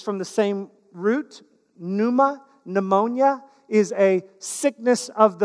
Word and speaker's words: pneuma: The New Testament word for Spirit is pneuma. Pneuma. It's got a silent P.